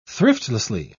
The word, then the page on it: thriftlessly: British English